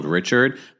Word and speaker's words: Richard: I know it's spelled Richard, but I'm pronouncing it correctly. As someone that has spoken and interviewed Dawn Richard multiple times, that is the way you pronounce her last name.